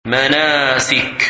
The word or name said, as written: Manasik